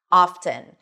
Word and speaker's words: often: However, at some point in the word often, the T started to come back, often. And the T is actually pronounced, something that we don't see in fasten or listen, right?